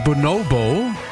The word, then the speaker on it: Bonobo: Ihr hört 1Live Plan B, jetzt mit Bonobo bzw. Bonobo, wie er ausgesprochen wird.